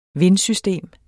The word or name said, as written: vindsystem